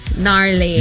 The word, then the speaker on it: gnarly: And then there's also the word garly. – Gnarly. Yeah, the G is silent.